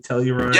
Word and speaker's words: Telluride: Telluride? – Yeah.